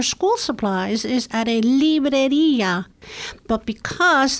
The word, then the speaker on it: librería: So what, I have trouble with the pronunciation. Libreria? – Libreria.